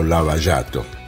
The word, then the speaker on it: Lava Jato: de toda una operación llamada Lava Jato, o Lava Jato, para pronunciarlo de la mejor manera posible.